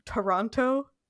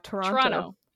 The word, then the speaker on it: Toronto: It's like one syllable. You only pronounce one T if you're lucky. – Yeah. Usually it's a CH. – Yep. But yeah, Toronto.